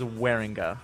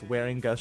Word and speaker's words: Wieringa: My last name is Wieringa. Like, I always tell people, like, it's Wieringa shirt.